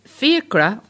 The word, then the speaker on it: fiacre: The word fiacre, or fiac, as it's pronounced in French, is the word for a hackney coach in France, and even in Hungary.